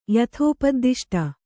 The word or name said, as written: ಯಥೋಪದ್ದಿಷ್ಟ